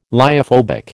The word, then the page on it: lyophobic: American English